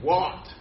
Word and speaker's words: wot: And the word W-O-T, what? Most of us can't even pronounce it.